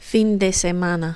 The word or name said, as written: fin de semana